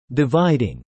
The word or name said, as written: Dividing